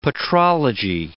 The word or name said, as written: petrology